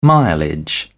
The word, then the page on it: mileage: British English